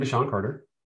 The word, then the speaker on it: DaShaun Carter: I am DaShaun Carter.